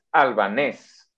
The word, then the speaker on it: albanés: Y sí, sí es albanés, así con acento en la E.